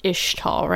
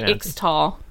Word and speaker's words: Ixtal: It's pronounced Ishtal, right? Like Ish. – Yeah. – I was going to say Ixtal, but we don't say the X, right? – Yeah, I think it's, yeah.